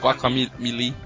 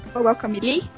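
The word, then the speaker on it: Guacamelee: É, não sei como pronuncia, é guac guacamali? É isso? – Guacamili. – Guacamili? Isso. Isso, a gente jogou muito, cara.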